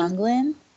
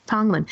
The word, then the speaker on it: Tonglen: When you just mentioned that, how do you pronounce it again? The Tonglen meditation? – Tonglen. – Tonglen.